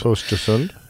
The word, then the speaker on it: Östersund: Gothenburg to Stockholm and via going almost due north up to, we'll hear the pronunciation of this area, Östersund. – Östersund, yes.